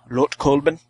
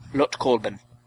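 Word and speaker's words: Lötkolben: Lut. It's got that umlaut. I don't... Lutkolben. – That's how you say it in German. – Lutkolben.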